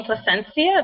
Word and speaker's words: Placencia: If anybody wants to find me on Facebook, and my name's Elizabeth, last name Placencia, it's spelled PLACENCIA.